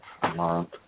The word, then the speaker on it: amaranth: I think that's how you pronounce that. Amaranth. Amaranth. – Amaranth, yes.